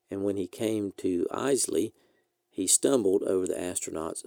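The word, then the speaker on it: Eisele: It should be pronounced like Isley.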